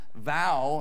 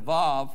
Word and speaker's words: Vav: For example, Vav can be pronounced Vow instead of Vav, for example.